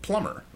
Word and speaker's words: plumber: It's in the middle of plumber, and I don't pronounce the B there.